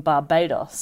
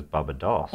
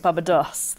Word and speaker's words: Barbados: So he was a wealthy gentleman from Barbados. Sorry. Am I saying that right? – Well, I think the one you're meaning to say is Barbados. – Barbados.